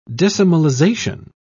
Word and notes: decimalisation: British English

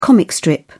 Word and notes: comic strip: British English

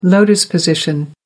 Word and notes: lotus position: American English

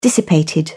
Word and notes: dissipated: British English

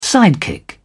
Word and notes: sidekick: British English

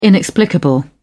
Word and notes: inexplicable: British English

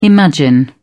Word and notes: imagine: British English

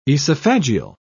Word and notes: esophageal: British English

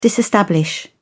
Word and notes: disestablish: British English

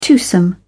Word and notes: twosome: British English

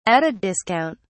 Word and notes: at a discount: British English

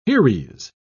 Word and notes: heres: British English

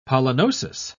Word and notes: pollinosis: British English

